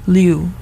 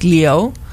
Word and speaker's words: Liu: It's Elisa Liu. I think it's really like Leo.